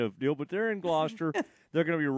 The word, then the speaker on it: Gloucester: So I think that is pronounced Gloucester. – Gloucester. – Is how you would do that. – Gloucester. – Yeah, from a British standpoint.